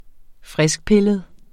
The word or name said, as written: friskpillet